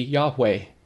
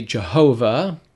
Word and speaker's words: Yahweh: And so the closest we can come to the name of God, the Yod-Heh-Vav-Heh, would be Yahweh. Now, some say Jehovah. That's more of a Germanic pronunciation, but Yahweh would be the closest, which is actually the doubling of the Hebrew verb hayah, which is the verb to be.